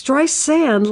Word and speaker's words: Streisand: It's Streisand like sand on the beach.